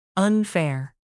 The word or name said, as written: unfair